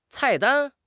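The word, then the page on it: cai4 dan1: tone 4; tone 1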